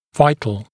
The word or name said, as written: vital